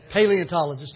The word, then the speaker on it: paleontologist: He was a pantologist. People that excavate. Did I say that right? Paleontologist. Thank you.